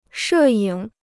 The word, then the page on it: 摄影: shè yǐng